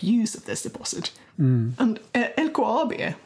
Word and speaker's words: LKAB: So the next step for LKAB, I'm going to say LKAB because that's how you pronounce it in Swedish.